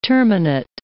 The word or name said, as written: terminate